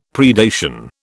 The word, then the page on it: predation: British English